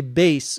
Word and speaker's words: bass: A bass, B-A-S-S, player. Notice the pronunciation here. This word is pronounced like a long A, bass. If you pronounce it bass, same spelling, that means something completely different. That would be a fish.